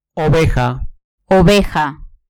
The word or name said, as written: oveja